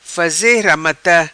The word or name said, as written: faze ramata